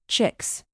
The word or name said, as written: chicks